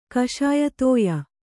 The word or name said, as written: ಕಷಾಯತೋಯ